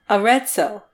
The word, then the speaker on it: Arezzo: Yeah, it's like a hard T. The double Z is, it's like Mozart. So Arezzo. – Arezzo.